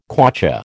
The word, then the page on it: kwacha: American English